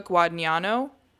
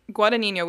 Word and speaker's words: Guadagnino: It was directed by Luca Guadagnino. Guadagnino, we think the G is silent.